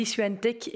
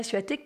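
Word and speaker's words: Świątek: Oui, je dis Swiatek et pas Suatek parce que c'est comme ça qu'elle aime bien qu'on prononce son nom. En tout cas, la 54e joueuse mondiale a dominé en finale l'américaine Sofia Kenin 6-4-6-1,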